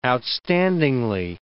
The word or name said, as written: outstandingly